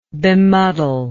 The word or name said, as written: bemuddle